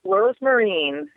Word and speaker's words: Rosmarin: Did I say that right? – Yes, Rosemarine. It's actually like a marine, like the boat.